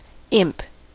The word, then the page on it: imp: American English